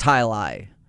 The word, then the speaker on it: jai alai: The word itself or the words itself are spelled J-A-I-A-L-A-I. – Okay, but it's pronounced Hi-Li.